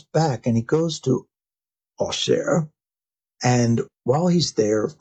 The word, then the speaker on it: Auxerre: Well, what is Auxerre? A-U-X-E-R-R-E, right? So that is the French pronunciation.